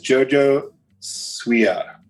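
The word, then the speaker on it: JoJo Siwa: Her name is Jojo Suia. I think I said that right. If not, Mick can slap me because she and I had her go over it with me several times and I still think I said it wrong. But I don't think you did.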